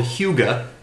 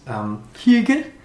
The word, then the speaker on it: hygge: mine is something that may or may not be called hygge. It's a word in Danish, which I don't speak. It's spelled H-Y-G-G-E, which is apparently a part of Danish culture, which is... – hygge? – Maybe hygge. That is the feeling of warmth and togetherness you get by relaxing with people you love.